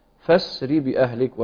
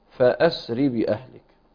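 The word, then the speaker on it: فاسر: قول الله تعالى: فأسر بأهلك، وكذلك أن أسر بأهلك، قرأها نافع وابن كثير بهمزة وصل، ومعنى أنها تقرأ بهمزة وصل أنها لا تلفظ أثناء الوصل، هكذا فسر بأهلك وليس فأسر بأهلك. وأن أسري هكذا تقرأ أنسري بأهلك بقطع من الليل.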